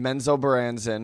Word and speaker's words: Menzoberranzan: Drizzt is born in Menzoberranzan.